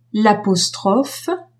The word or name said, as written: l'apostrophe